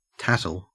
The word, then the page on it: tattle: Australian English